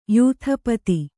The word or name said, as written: ಯೂಥಪತಿ